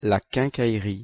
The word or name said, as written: la quincaillerie